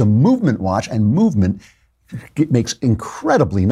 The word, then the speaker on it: MVMT: It's spelled MVMT, so you don't have to pay for all those expensive vowels.